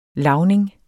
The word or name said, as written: LAVNINGERNE